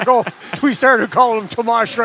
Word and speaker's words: Tomas: Back in the day when Tomas Hertl was just a rookie, he came up and told Randy Hahn and I that he'd like his name pronounced Tomas, not Thomas.